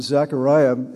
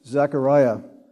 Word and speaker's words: Zechariah: So after the service last week, a couple of you wondered why I kept referring to the name Zechariah as opposed to the name Zacharias. Both of those names are legitimate spellings or pronunciations of the name.